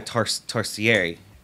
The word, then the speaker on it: tarsier: You're saying a tarsier is actually how it's pronounced. – Tarsier? – But like a dossier? – Yes, tarsier.